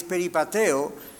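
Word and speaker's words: peripateo (περιπατέω): La palabra griega para andar es peripateo, o se pronuncia así, y significa conducirse en las actitudes y acciones diarias.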